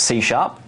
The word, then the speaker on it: C sharp: It's pronounced C sharp. And that's why I keep saying C-sharp. It's interesting we have that on the slide. – It looks like C-pound is why.